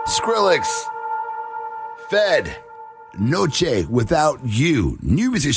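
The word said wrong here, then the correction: Feid: Actually, it's pronounced Fade. It's spelled F-I-E-D, but Fade is the artist.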